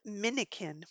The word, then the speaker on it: Minikin: So the site, the company is called Minikin, which is spelled M-I-N-I-K-I-N, like mannequin, but with eyes.